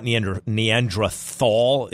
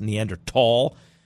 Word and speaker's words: Neanderthal: Well, it turns out these Neanderthals, and yes, it's pronounced, I'm going to be annoying, it's not Neanderthal, it's supposed to be pronounced Neanderthal. And since I studied this in college, I annoyingly say tall instead of thal.